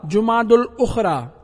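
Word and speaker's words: جمادی الآخرہ: صحیح نالو این صحیح تلفظ لغت جے اعتبار سا انن بینی مہینن جا صحیح این درست تلفظ ہی آہن جماد الاولا جماد الاخرا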